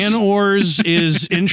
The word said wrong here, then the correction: Jyn Erso: It's Jyn Erso. – Ben. – Mispronunciation. – Yeah. – Because we all know right it's Jan Ors. It's all Jyn. Jyn Erso is the name.